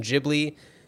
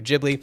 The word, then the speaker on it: Ghibli: we have decided to go with the pronunciation Ghibli. That is the way that Miyazaki pronounces it, so we are going to be saying Studio Ghibli.